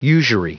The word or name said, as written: usury